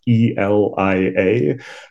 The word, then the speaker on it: Elia: And just because I know my name trips people up sometimes, my name is spelled E L I A.